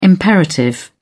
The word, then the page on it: imperative: British English